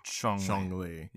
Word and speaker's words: Zhongli: So it's a Z, but it's a, you say it like a CH. – Yeah. – Zhongli.